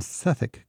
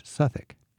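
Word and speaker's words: Southwark: But in Suffolk Cathedral, Southwick, but pronounced Suffolk.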